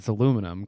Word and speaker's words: aluminium: You looked straight at me when you said it, and I can't take you seriously when you say methane. It's like aluminium. – Aluminium. – Did I say it right? – Yeah, you did. – Okay. – Well, no, you said it wrong. Aluminium. But it's aluminum, because where the hell did they find that extra I? Like, seriously.